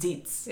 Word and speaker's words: Xeets: It's, yeah, it's an X-E-E-T. – So I feel like it would almost be pronounced Zeets. – Yeah. – Right? – Yeah. – Zeets.